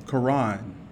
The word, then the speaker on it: Kahron: We'll get into my background a little bit, but how do you say your name? Quran. Oh, like the book?